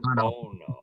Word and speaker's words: Bono: Bono.